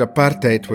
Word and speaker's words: apartheid: Well, I'll be pronouncing it apartheid, which is the Afrikaans way of pronouncing it, and that means separateness.